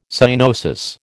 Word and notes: cyanosis: American English

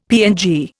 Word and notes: PNG: Indian

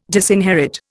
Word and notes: disinherit: Indian

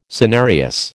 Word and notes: senarius: American English

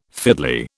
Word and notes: fiddly: British English

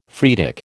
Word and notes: phreatic: American English

